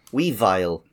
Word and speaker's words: Weavile: Okay, here is the Pokedex entry for Weedile. I just pronounced that wrong. Weavile.